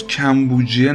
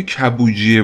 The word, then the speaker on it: کبوجیه: اسم درست پدر و پسر بزرگ کوروش تو تلفظ کمبوجیه نبوده بلکه کبوجیه بوده یعنی اون میم بعد از کاف وجود نداره کبوجیه بدون میم کلمه هستش